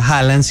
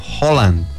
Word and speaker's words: Haaland: ¿Cómo les parece que esto es el dialecto o idioma noruego y el apellido de él no se pronuncia Haaland, sino Holland? – Holland. – Uh-huh. Ah, porque tiene doble A. – Sí. – Y esa doble A, según por ahí estuve leyendo, al momento de unirse queda como una O.